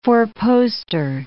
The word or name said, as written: four-poster